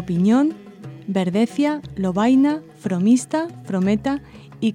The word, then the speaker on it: Piñón: Pinón es un arcaicismo de Piñón anterior a la gramática de Nebrija. Se escribe con todas las letras unidas, Pinón, y su pronunciación correcta era y debería ser Piñón.